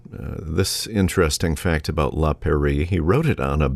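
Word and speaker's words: Péri: It's spelled P-E-R-I, not like the French pronunciation of Paris, but Perie, P-E-R-I, and was his last completed work, which he wrote at the age of 47.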